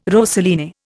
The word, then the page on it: rosaline: Indian